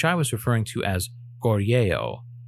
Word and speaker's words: Goryeo: Romanization varies wildly between East Asian languages, even those as geographically close as Japan and Korea. A closer pronunciation is Gorya, which I shall be using from this day forward.